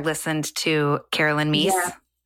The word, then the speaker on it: Myss: And it was coming in and listeners, just Google Carolyn Meese. That is how you say her name, I think. M-Y-S-S, Sacred Contracts, and your mind will be blown.